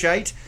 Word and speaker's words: Scheidt: Um, it is yes. pronounced Shite.